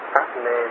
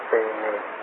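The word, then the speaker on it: padme: Chứ không khi, tại vì tiếng Pháp Nê đó, tức là hoa sen đó, thì tiếng Nhật Bản nó đọc là Tê Nê. Ôm Mani Tê Nê Hùng. Tiếng Pháp nó là trong khi là Ôm Mani Pháp Nê Hùng. Tiếng Nhật Bản nó cũng bị chạy chút xíu.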